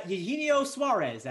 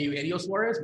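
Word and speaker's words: Eugenio Suárez: I would actually say his name correctly, and I would call him Eugenio Suarez, but that's just me.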